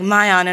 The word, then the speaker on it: MyAun: Like, I walked in and they were like, MyAun, and I was like, that's never happened.